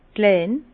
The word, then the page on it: klein: Bas-Rhin; Herrlisheim